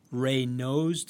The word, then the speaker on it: Raynaud's: On top of that, you have something that's called Raynaud's disease.